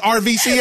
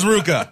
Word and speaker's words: RVCA: Yeah, my clothing sponsorship with Ruka. It's a great company. And oh, see, no, I never knew how to say it. I always thought it was RVCA.